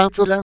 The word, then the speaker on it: Zola: Peggio è andata a Anzola, si legge con l'accento sulla A, essendo francese, trattato come un parente del calciatore sardo Gianfranco Zola.